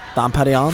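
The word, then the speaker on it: Dom Pérignon: Dom Perignon.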